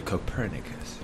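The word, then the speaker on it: Copernicus: En realidad se pronuncia Copérnicus. Pero sí.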